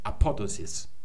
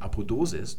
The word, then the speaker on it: Apodosis: Und im Deutschen wird auf der Penultima sowas betont. Das heißt also Protasis und dort, wo betont wird, wird auch noch gelenkt und Apodosis. Das ist die richtige Betonung.